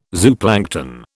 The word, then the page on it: zooplankton: British English